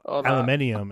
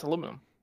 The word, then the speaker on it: aluminum: Well, aluminium is not how you say it. – No, it's aluminum. – Yeah, it's aluminum. I agree.